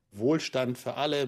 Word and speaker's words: Wohlstand für alle: Wohlstand für alle, which means material well-being for all.